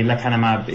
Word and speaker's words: lecanemab: The lecanemab is the most recent.